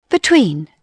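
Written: between

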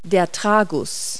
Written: tragus

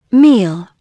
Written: meal

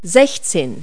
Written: sixteen

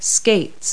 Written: skates

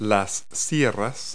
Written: saws

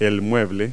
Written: cabinet

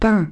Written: peins

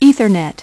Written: ethernet

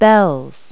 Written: bells

